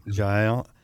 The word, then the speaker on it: Jael: You relate the story of Jael. Is that how you say it? – Jael.